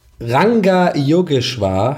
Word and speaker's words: Ranga Yogeshwar: Wie spricht man das? Yoga. Ranga Yogeshwar heißt der.